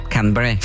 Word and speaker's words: Canberra: Cranberry. Jeg husker ikke helt hvordan du uttaler, men Cranberry. – Ja, det riktig. – Canberra.